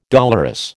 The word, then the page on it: dolorous: American English